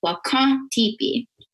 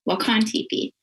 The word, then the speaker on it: Wakan Tipi: And so Wakan, it's that there's an N at the end of that, but it's not fully pronounced. So we say that those are nasal N's. So when we're saying Wakan, it's that like nasal N. So Wakan, Wakan Tipi.